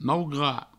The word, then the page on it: maugrat: mau-grat